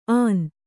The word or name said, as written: ಆನ್